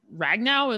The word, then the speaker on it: Ragnow: And then the Lions center, Frank Ragnow, am I pronouncing his last name correctly? – Yes, he fractured his throat in the first quarter of the game, and he played for the rest of the game.